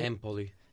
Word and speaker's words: Empoli: And then they finish out Empoli? Is that how you say it? – Empoli.